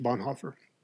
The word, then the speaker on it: Bonhoeffer: And that's what really grabbed me about Dietrich Bonhoeffer. I know his name is correctly pronounced Dietrich Bonhoeffer, but in the interest of us American speakers, I'll keep the American pronunciation.